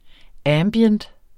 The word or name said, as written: ambient